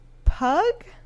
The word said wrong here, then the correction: Pugh: P-U-G-H? – Mm-hmm. – I think it's Pugh. – Okay. I mean, Florence Pugh. – That's how I've heard it pronounced before, but I could be wrong. – That sounds right.